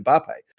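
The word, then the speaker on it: Mbappé: By the way, as an aside, Mike, congratulations on the correct pronunciation of Kylian Mbappe.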